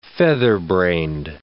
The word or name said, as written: featherbrained